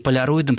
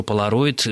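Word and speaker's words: Polaroid: Если мы будем произносить это на, скажем так, английский или европейский манер, то слово должно звучать как «поляроид». Но я сам произношу «полароид», в моей жизни это слово вошло именно в таком форме,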